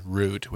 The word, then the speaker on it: root: Let's understand the proper pronunciation is root with an oo sound in there, not rut, or however you say it.